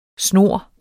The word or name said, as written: snor